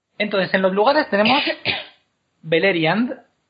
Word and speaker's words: Beleriand: Pues Así es como hay que pronunciar también en el turco. Entonces es Beleriand.